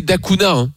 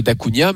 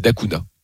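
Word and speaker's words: Da Cunha: Tout est ouvert autour de ce joueur dont il faut prononcer Dacuña. C'est contre-intuitif. Parce qu'il est d'origine portugaise, donc on était tous persuadés que ce serait Dacuña, mais il a dit non, c'est Dacuña.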